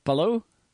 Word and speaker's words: Palou: Alex, and I don't know if I'm saying his name right, Palou.